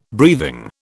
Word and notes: breathing: British English

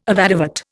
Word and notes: avadavat: Indian

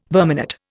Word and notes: verminate: Indian

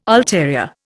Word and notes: ulterior: Indian

